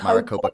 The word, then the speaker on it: Maricopa: One was, I'm not going to be able to pronounce it correctly, Maricopa. – Maricopa County, yes.